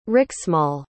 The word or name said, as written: riksmål